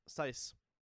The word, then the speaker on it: Seuss: The Dr. Seuss actually is, it rhymes with voice, not loose. – Yeah, Seuss.